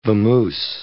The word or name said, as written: vamoose